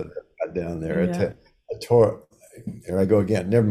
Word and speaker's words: Aotearoa: I'll just say, if I'm saying it right, Aotearoa. – I can never say it. It's Aotearoa.